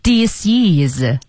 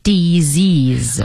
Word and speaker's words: disease: 但注意哦它的读音不是 disease 它的读音叫做 disease 啊，就是那个 S 发成 Z 的音和后面的元音连在一起了